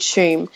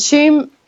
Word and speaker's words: Tuam: Shum. And Shum, it's spelled T-U-A-M, is this little town north of Galway.